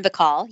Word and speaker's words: Bacall: Yes, Lauren Bacall.